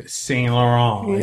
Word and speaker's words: Yves Saint Laurent: It is Yves Saint Laurent.